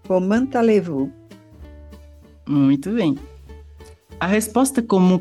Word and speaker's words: vous: E uma pequena observação sobre a pronúncia é que escrevemos vu com V, O, U, S, mas a gente não fala o S na pronúncia. A gente apenas fala VU.